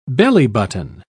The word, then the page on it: bellybutton: British English